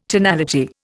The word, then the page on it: tonality: Indian